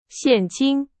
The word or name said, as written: 现金